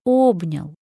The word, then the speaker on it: обнял: Мне представляется, что в слове «обнял» «о» — это главное гласное, которое берет на себя ударение. Это сплетенные руки буквой «о». Вы обнимаете этой буквой «о» и говорите «обнял», «обнял меня».